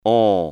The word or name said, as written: on